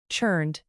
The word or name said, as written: churned